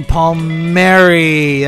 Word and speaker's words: Palmieri: For that long-titled Eddie Palmieri. That's how you pronounce it.